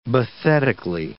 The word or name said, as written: bathetically